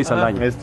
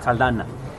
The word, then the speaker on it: Saldaña: Soy Saldaña. – Soy Saldaña. – Saldana. – Saldana. Sí, automáticamente te conviene al Saldana. – Saldana, porque son muy estúpidos y no saben pronunciar.